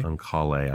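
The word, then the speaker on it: Oncale: Supreme Court, because several years ago there was this case called, and I always forget how to pronounce the plaintiff's name, whether it's On-Call or On-Call-A. – On-Call-A, as well, I always say it. – On-Call-A v.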